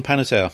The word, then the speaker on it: Panettiere: Hayden Panettiere.